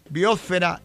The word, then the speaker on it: biosfera: De todas ellas, solo la palabra atmósfera es esdrújula. Las demás son graves y llevan el acento sobre la sílaba fe. Estratosfera, ionosfera, biosfera, litosfera o hidrosfera.